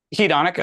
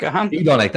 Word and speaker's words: hedonic: How do you pronounce that word? Hedonic? – Hedonic. – Hedonic. That's the one.